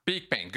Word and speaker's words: Beakbane: Beakbane, good.